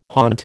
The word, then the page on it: haunt: American English